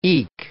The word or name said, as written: eek